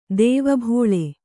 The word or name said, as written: ದೆವ್ವಬಾಳೆ